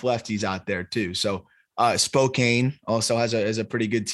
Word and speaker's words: Spokane: I believe you, because like, it's your job to know that, but there's an E in there. It's Spokane is a very, very fair, I feel like, pronunciation there. – I understand. – I'll wear Zach Veen being too low. I'm gonna just say it should be Spokane.